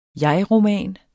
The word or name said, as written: jegroman